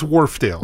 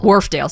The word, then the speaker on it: Wharfedale: is it Wharfedale? How do you say it? – It's Wharfedale. – Wharfedale. Sorry, I add an extra letter.